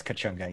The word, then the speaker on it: Kachunga: Kachunga, I believe it's pronounced, yeah. – Elias Kachunga, yes.